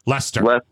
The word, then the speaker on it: Leicester: Leicester. I can never pronounce that right. – Leicester. Yep, Leicester.